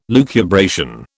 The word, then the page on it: lucubration: British English